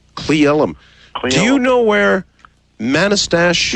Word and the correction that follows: Manastash: Yeah, it's pronounced Manastash.